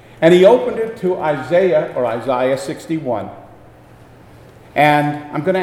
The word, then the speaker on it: Isaiah: By Jesus taking and opening the scroll of Isaiah, or as the deacon said this morning, the British pronunciation, Isaiah.